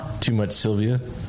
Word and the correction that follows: Sylvie: Is that right? Am I saying that right? – Sylvie. – Sorry.